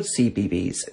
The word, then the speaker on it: CBeebies: So it's called CBeebies, which is spelled C-B-E-E-B-I-E-S.